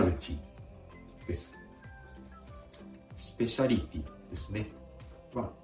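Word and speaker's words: spécialité: スペシャリテ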